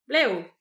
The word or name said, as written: blev